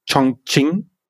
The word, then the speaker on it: Chongqing: Wir fangen halt eben in Chongqing, glaube ich, wird ausgesprochen.